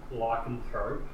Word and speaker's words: lycanthrope: Lycanthrope.